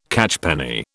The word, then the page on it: catchpenny: British English